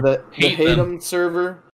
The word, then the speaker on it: Hadum: I don't know how to say this. Haddam, I guess? – Yeah, Haddam.